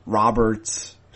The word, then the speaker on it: Robert: His last name is spelt like Robert and is pronounced actually more similar to Robert than one might expect of a person who was born and raised in Cuba.